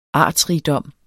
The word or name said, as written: artsrigdom